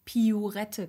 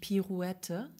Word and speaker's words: Pirouette: Ich habe ja auch lange Jahre ähm, Piurette gesagt und nicht Pirouette. Ja.